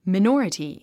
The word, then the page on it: minority: mainɔ́ːrəti; マイノーラティ